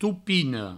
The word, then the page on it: topina: toupino